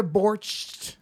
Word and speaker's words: borscht: I had a borscht. Is that how you say it? – Borscht. – Yeah. Borscht.